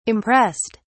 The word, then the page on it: impressed: British English